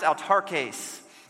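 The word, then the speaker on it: autarkes (αὐτάρκης): And I want to hang on to that word content for a moment, because in the Greek, this word content is actually pronounced autarkes.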